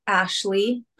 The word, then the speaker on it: Ashley: um i am on instagram as coach ashley s and um my name is spelled a s h l e y but that's where you can find me on Instagram.